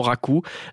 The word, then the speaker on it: raku: R-A-Q-U. Ça se prononce raku. Non, ça se prononce raku. Ça se prononce très clairement raku.